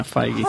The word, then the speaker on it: Feige: Well, so, now, Kevin Feige said, oh, no, I said his name right. – Feige. – Damn it. – How'd you learn it? – Is that how you say it? – On the show. – Feige. – He'll never come on the show. No. – Feige? – Yeah, Feige. Huh.